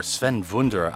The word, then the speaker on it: Sven Wunder: Now after that we had Sven Wunder, or Sven Wunder, I believe is the proper pronunciation, although I don't speak Swedish.